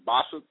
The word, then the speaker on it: Bossip: It's spelled B as in boy, O-S-S-I-P, like gossip, but I guess it's Bossip, whatever.